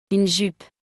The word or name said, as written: Une jupe